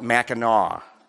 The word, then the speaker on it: Mackinac: It's spelled Mackinac, but they call it Mackinac.